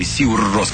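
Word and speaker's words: Sigur Rós: Сигур Рос, или Сигур Рос, как правильно говорит на исландском.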